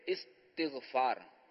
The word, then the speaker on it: استغفار: ہاں, اس میں یہ ہے کہ یہ جو صحیح تلفظ ہے نا, یہ استغفار, استقبال, استغفار, اس کے وزن پر, استقبال کے وزن پر,